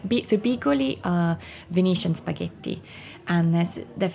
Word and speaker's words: bigoli: Bigoli? – Bigoli in salsa.